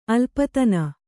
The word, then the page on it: ಅಲ್ಪತನು: alpatanu